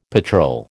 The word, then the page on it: patrol: American English